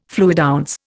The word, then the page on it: fluidounce: Indian